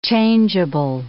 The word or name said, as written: changeable